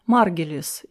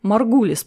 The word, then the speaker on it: Маргулис: Услышав название протоктисты вместо привычного протисты, простейшие, я поинтересовался у собеседницы, не знакома ли она с Лин Маргелис или Маргалис, которая пустила в оборот этот термин. Я не знал, как правильно произносится эта фамилия. Маргулис, поправила она, это я.